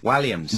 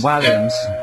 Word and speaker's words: Walliams: Walliams. – Walliams.